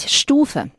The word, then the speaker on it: Stufe: Lo pronunciamos con una U algo alargada. A ver, dilo tú. Vorsicht, Stufe.